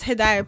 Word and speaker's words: Hidaayah: And it's pronounced Hidayah. People say Hidayah.